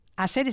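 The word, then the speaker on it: Asere: A Sere, ¿se pronuncia así? – A Sere.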